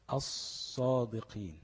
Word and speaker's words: الصادقين: الصادقين، أص مش ال، لا، أص مفيش لام، قلبت لصاد وادغمت الصاد في الصاد، بقت ايه؟